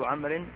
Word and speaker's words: عمرو: الواو لا تنطق. – ولفظ عمرو الحجر كله مقام إبراهيم.